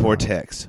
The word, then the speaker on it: Tortex: Is it Tortex or Tortix? I feel like I said it Tortex. – Wrong. Yeah, I said it wrong the first time.